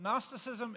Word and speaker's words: Gnosticism: The G is silent in Gnosticism, and the C's sound like S's because English is dumb. But Gnosticism and Docetism.